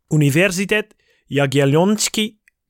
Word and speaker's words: Uniwersytet Jagielloński: And I'm going to try and make an attempt to pronounce it Ooh. As it should be. So, Uniwersytet Jagielloński Kraków.